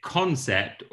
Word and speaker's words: Concept: Is it Concept or CN? How do you like it pronounced? – Concept. – Thank God.